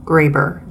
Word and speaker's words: Graber: It's Dr. D-R-S-A-M Graber. G-R-A-B-E-R. So that's D-R-S-A-M-G-R-A-B-E-R.